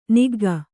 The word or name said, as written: ನುಗ್ಗು